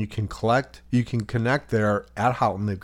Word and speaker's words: Houghton: she wouldn't go for the Houghton. – In reality, I'm a little bit more intelligent than he. – But it's pronounced Houghton, and so there's two things that you can, there's two ways to get to the island from Houghton.